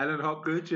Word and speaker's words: Alan Hopgood: Alan Hopgood, yeah.